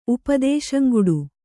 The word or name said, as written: ಉಪದೇಶಂಗುಡು